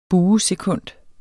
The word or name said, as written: buesekund